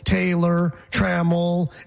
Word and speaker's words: Taylor Trammell: There's two syllables, Taylor Trammell, and then it goes down and up.